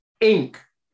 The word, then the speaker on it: Inque: Right, so the magazine they've come up with is called Ink, but it's spelt I-N-Q-U-E.